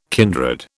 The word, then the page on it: kindred: British English